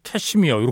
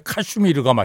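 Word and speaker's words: Kashmir: 여기 뭐 영미권에서는 캐시미어 이렇게 발음하는데 예. 원래 카슈미르가 맞습니다. 아, 지명이거든요.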